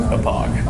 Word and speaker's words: Hauppauge: Looks like it should be said Hopage. – It's the Hopog River in New York. – Named after the city. – Can you spell it for me, please? – Yes, because it's on the remote. – How do you spell? – HAUPPAUGE. – Oh, is that how you say it? Hapog? – Hapog.